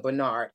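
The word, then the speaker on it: Bxrnxrd: So Bernard is his decolonized musical identity, but the Bernard is spelled like B_X_R_N_X_R_D. All right, but you pronounce it Bernard.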